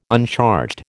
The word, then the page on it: uncharged: American English